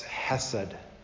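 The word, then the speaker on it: hesed (חסד): The word there, kindly, is a Hebrew word we would pronounce hesed.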